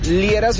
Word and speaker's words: Liera: Fernando Liera, se escribe L-I-E-R-A. L-I-E-R-A.